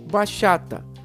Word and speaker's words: bachata: Bachata pronuncia-se bachata.